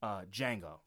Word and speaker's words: Django: Django. Love Django. – Okay. – Django is fucking. I would watch Django every day. It is the D is silent, motherfucker.